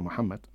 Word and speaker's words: Muhammad: Y luego, que el profeta Mahoma, el profeta Muhammad, en árabe se pronuncia Muhammad, no es Mahoma, pero la traducción al español Mahoma, es el último profeta que vino a dar una religión monoteísta, conocimiento a los ciudadanos.